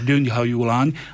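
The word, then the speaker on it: Gyöngyhajú lány: Ale áno, akurát ten akcent sa nie háju, ale háju, zdá, Gyöngyhájuláň.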